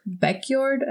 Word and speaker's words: Beckjord: This guy, Jon Erik Beckjord, I think that's how you say his name. It's B E C K J O R D, was a mm-hmm.